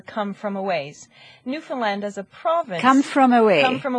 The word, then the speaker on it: Newfoundland: To give you an example, in Newfoundland, and it's pronounced that way even though it's spelled Newfoundland. – But it is pronounced Newfoundland.